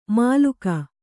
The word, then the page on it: ಮಾಲುಕ: kannada; māluka